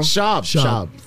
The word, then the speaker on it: Schaub: If Mr. Schaub get his own team, I want. Schaub. Did I say it wrong? – Schaub.